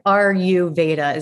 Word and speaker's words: Ayurveda: Ayurveda? – Veda, yeah. Okay. – So it's like Ayurveda is how you kind of say it.